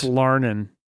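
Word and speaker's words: larnin': learning. And she's not quite these hayseeds. – That's Larnin. – Larnin, sorry, I mispronounced it.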